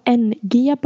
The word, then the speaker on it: Ng: Stavas NG bara efternamnet, så att Celeste Ng tror jag det uttalas.